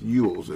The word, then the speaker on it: Elwes: Elwes. – It's a very E-L-W-E-S.